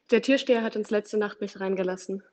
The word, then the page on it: Türsteher: German